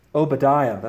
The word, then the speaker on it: Obadiah: And so we see God promise destruction in the book of Obadiah.